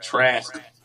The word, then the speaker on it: Trask: He's not Trask. Trask. – There you go. – I gotta pronounce the man's name right.